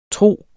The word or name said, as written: tro